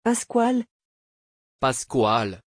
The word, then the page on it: pascual: French